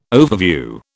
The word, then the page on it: overview: British English